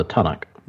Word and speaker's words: Tanakh: Oh, the Tanakh. – Yeah, Tanakh. Thank you. I didn't know how to pronounce it.